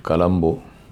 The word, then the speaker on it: Colombo: As the locals pronounce it Colombo,